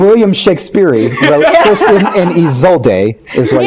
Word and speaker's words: Isolde: Isolde.